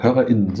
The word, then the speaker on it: HörerInnen: Wie, wie spricht man dieses, äh, ja. ja. HörerInnen, so eine kleine Pause mhm. macht man, ne?